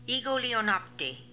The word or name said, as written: Egolionopty